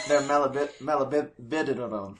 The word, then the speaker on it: molybdenum: Yeah, because of their molybdenum mine. They're molybdenum. – Good job. Nailed it.